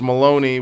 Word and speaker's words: Maloney: so my last name is Maloney, which rhymes with baloney.